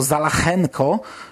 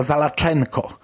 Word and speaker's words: Zalachenko: Mamy tak naprawdę jedną taką istotną różnicę w wymowie nazwiska. Gosztyła czytał to Zalachenko, e, to był Rosjanin i on czytał to w taki sposób, natomiast Adam Bauman czyta Zalaczenko. I to trochę wkurza.